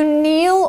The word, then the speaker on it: kneel: All right, so to kneel or kneeling, you can say you can see this is spelled with a K N E E L. The K is silent.